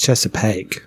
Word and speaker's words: Chesapeake: It's pronounced Chesapeake.